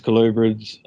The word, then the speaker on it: colubrid: The colubrid, is that how you pronounce it? – You got a lapids, colubrids.